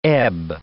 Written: ebb

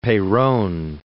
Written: Peron

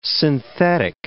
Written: synthetic

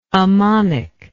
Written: ammonical

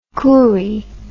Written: courie

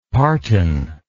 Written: partan

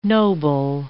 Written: noble

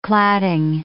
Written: cladding

